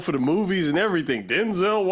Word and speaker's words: Denzel: By the way, did you hear that his name is not pronounced Denzel? It's pronounced Denzel.